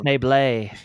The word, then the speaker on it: Schneebly: Uh, it's written and directed by Mike White, who um, is uh, best known as uh, Mr. Schneebly. Yeah, School of Rock. so he wrote and and actually, Andy, it's pronounced Schneeble.